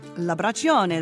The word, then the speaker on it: L'Abbraccione: Am I pronouncing that correctly? – L'Abbraccione, that's perfect.